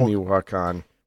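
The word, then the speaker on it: Michoacán: Strange phenomenon observed in the skies of Michoacan. – Did you say Milwaukee? – I have no idea if this is right. It looks like Michoacan. I'm guessing it's Michoacan. – It is Michoacan.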